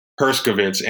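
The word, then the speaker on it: Herskovits: And I think this guy, I'm probably pronouncing his name wrong, Heskovitz, kind of made a very good case for showing that there is a lot of African culture. Herskovitz.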